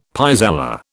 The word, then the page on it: pis aller: British English